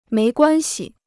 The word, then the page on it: 没关系: méi guān xi